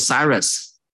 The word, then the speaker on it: Cyrus: 他的读作 Cyrus，那这时候呢，差不多是 538年、 529年，在这个时候开始了啊，他们开始归回了，这是很奇妙的一件事情哈， 538年、 529年开始归回。